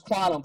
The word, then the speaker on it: Qtum: And I just happened to get lucky because when I think it's pronounced Quantum, but I call it Qtum because of Q-T-U-M.